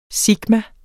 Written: sigma

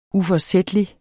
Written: uforsætlig

